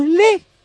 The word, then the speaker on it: Le: A las 6:08 de la tarde, los doctores Mimber, Wang, Valles Luco y Le, no Li, sino Le, nosotros somos españoles y pronunciamos así, se pusieron a anestesiar al herido para asuntarle y buscar el proyectil más peligroso.